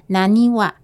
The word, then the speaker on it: 浪速: 日治时代此地称为浪速，日文发音为 naniwa，意味着大浪急涌。